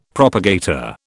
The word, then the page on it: propagator: British English